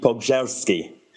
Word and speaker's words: Pogorzelski: Well, oddly, a Polish concierge this weekend told me that I've been saying it wrong for my entire life. So it's apparently Pogorzelski.